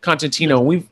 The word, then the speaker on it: Kontentino: And it's Kontentino, right? That's how you say it. Kontentino.